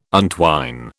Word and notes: untwine: British English

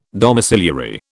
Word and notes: domiciliary: British English

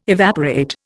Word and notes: evaporate: Indian

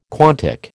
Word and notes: quantic: American English

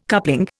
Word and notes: coupling: Indian

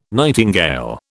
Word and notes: nightingale: British English

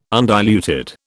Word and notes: undiluted: British English

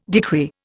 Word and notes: decree: Indian

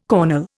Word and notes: cornel: Indian

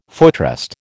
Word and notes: footrest: American English

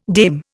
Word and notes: dame: Indian

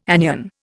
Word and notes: anion: Indian

